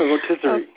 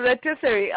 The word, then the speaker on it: rotisserie: but I saw how you worked through in making a rosteri. Am I saying that right? – A rotisserie. – Okay. Thank you, rotisserie.